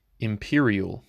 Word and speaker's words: empyreal: The first word that I have selected for this morning's episode is imperial. Imperial. It is spelled E-M-P-Y-R-E-A-L.